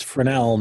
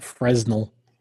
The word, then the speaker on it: Fresnel: And it's pronounced Fresnel, not Fresnel.